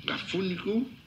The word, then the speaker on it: afónico: Claro, un poquito anfónico. ¿Afónico? Anfónico. – ¿Eufónico? – Anfónico. – ¿Cómo se dice? – Anfónico. – No. Afónico, de afoninga.